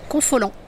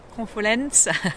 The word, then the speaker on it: Confolens: À Confolence, comment on dit Confolent? – Confolent. Oui, c'est piège. Il y a un S, mais on ne le prononce pas. – Nous, on le dit avec l'accent du sud, Confolens.